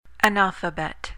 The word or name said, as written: analphabet